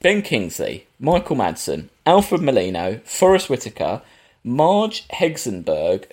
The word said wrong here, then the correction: Helgenberger: who is big from CSI, basically. – Helgenberger. – Helgenberger, thank you.